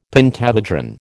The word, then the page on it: pentahedron: American English